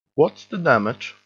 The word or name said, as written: what's the damage